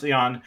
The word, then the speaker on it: Jan: It sounds like me, but it's French, so it's pronounced Jan.